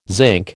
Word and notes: zinc: American English

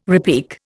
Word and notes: repique: Indian

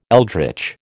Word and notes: eldritch: American English

